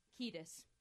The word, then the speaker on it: Kiedis: How do you say his last name? Kiedis. That is it.